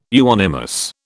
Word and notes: euonymus: British English

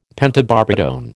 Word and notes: pentobarbitone: American English

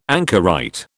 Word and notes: anchorite: British English

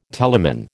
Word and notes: telamon: American English

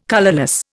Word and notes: colourless: Indian

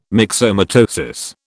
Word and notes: myxomatosis: British English

